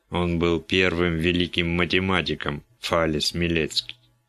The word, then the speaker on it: Фалес: А что интересного в том, что он взял себе псевдоним Фейлс?» «Ты неправильно произносишь». Впрочем, как и мистер Баллу. – Разве не Фейлс? – Нет, конечно. Напиши эту фамилию латинскими буквами, но прочти не по-английски, а произнося каждую букву. Что получится? – Фалес.